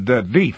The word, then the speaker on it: Death: "Pronounced Death, with a long E."